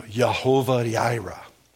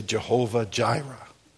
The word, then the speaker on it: Yehovah Jireh: You may say Jehovah Jireh. I used to say that until I heard some Hebrew guy say it, and then I thought, oh, I've been saying it wrong my whole life.